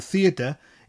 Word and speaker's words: theatr: The word for theatre, the English word theatre, is theatre, which has a slight r sound at the end of the word.